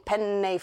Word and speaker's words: penne: And yes, I'm saying penne funny because I live with a Sicilian and I'm told that you have to pronounce both N's.